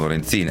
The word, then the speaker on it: Lorenzin: Devo dire che sono d'accordo con Stella, è veneto e si pronuncia Padoan, così come Lorenzin, la pronunciamo Lorenzin, anche se non è cresciuta in Veneto, se no dovremmo dire Lorenzin e Padoan, Padoan e Lorenzin, fine.